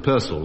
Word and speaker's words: Purcell: Yes, you mean you should have pronounced it Purcell, which is quite correct.